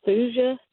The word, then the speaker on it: thuja: I've got some, I guess the way you pronounce it is thuja trees I just bought and planted.